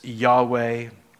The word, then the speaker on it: Yahweh: So we don't really know how to pronounce it. The best, most accepted way is Yahweh, and it simply means I am.